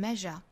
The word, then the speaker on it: measure: we're inadequate not that we're inadequate not that we're inadequate 好第一句话我们再来读一下 Our deepest fear is not that we're inadequate is not that we're inadequate 中间的 that we are 这三个字读得很快因为是虚词后面一句 Our deepest fear is we're powerful beyond measure. Our deepest fear is 在 fear 的后面我们可以停顿一次。 Our deepest fear is we're powerful. Is we're powerful. We are 读成 we're, we're. We're powerful beyond measure. Beyond 后面的 the 略掉。 Measure 注意中间的这个 s 读 r, r, r.